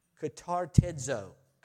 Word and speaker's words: katartizo: And the word comes from the same word that we find in Ephesians 4, from katartizid. I got to say this. Katartizid. That's what it is. Katartizid. You can laugh at me. You try it. K-A-T-A-R-T-I-Z, long O, D. Katartizo. I got it.